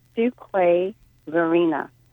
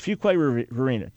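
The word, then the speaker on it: Fuquay-Varina: So how do you pronounce it, please? – Fuquay Varina. – All right, so the way it looks, Fuquay Varina.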